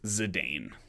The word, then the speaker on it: Zidane: I'll go with the correct pronunciation on everything else, but it's Zidane. That is Zidane.